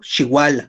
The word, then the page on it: xiuala: Náhuatl